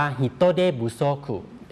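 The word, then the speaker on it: ฮิ โตเ ด บุ โ ซคุ (人手不足): ที่ออกเสียงว่าฮิโตเดบุโซคุ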